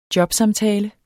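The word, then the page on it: JOBSAMTALER: Danish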